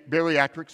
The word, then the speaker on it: bariatric: Now, some of you may know that besides being a tremendous godly example of a father, he is a bariatric surgeon. And I really need to pronounce that correctly, because if you kind of slur the words, it sounds like you're saying he's a barely adequate surgeon.